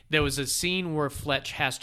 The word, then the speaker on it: Fletch: She played, every time she said his name, she pronounced it Flesh. She called it Flesh instead of Fletch.